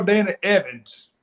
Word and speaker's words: Dana Evans: Now, my girl Dana Evans, hopefully I'm saying her name right. – You are. Yep.